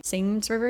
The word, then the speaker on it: Seine: Is that how you say it? The Seine River? Seine River, mm-hmm. yes. I was going to say it.